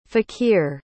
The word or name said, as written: fakhir